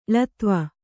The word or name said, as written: ಳತ್ವ